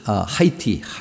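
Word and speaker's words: Haïti: 그리고 그 하이티 그 미국에서는 하이티라고 그러고 거기서는 IT라고 그러죠. IT 그 불란서형이기 때문에 H자를 발음 안 해서 IT라고 이렇게 얘기하는데 계속해서 거기 그동안 또 강이 그 아프터샥 여진이 또 일어나서 6.0인가 그랬죠?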